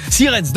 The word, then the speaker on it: Sierentz: Sierentz. Je crois que c'est un Z au début, mais c'est comme ça que ça se prononce en allemand, je pense. Sirens, dans le Haut-Rhin, foncez et donnez le mot de passe et la valise est à vous.